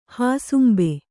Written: ಹಸುಂಬೆ

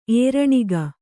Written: ಏರಣಿಗ

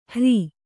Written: ಹಾರಿ

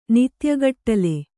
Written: ನಿತ್ಯಗಟ್ಲೆ

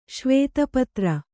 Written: ಶ್ವೇತಪತ್ರ

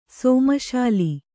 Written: ಸೋಮಶಾಲಿ

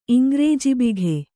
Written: ಇಂಗ್ರೇಜಿಬಿಘೆ

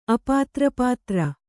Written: ಅಪಾತ್ರಪಾತ್ರ

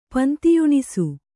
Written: ಪಂತಿಯುಣಿಸು

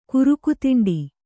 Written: ಕುರುಕುತಿಂಡಿ

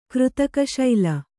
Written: ಕೃತಕಶೈಲ

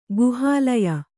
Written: ಗುಹಾಲಯ

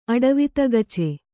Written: ಅಡವಿತಗಚೆ

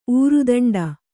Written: ಊರುದಂಡ

